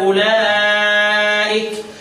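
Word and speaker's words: أولئك: اولئك اولياء اولئك مد طبيعي لان بعدها ايه متحرك هتقول لي ده في واو سواها اهي اقول لك لا الواو دي لا تنطق يعني رسم فقط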